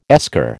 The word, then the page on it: esker: American English